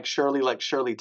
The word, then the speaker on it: Schirle: Now my last name, you know, isn't like Schirle Temple or Laverne and Schirle. It's spelled it's got a little German in it. Schirle.